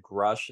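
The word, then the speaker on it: Grusch: Is that the Krush guy? – Yeah, Grush, or whatever you say. But David Grush is how people typically say it, but it's spelled G-R-U-S-C-H, I believe.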